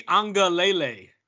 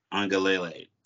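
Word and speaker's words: Uiagalelei: A little bit in the the alphabet kid, I can't even say his name, Ungalele. Ui Angalele. – Angalele.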